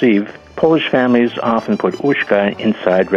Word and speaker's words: uszka: At Polish Christmas meals, people often eat something called uszka.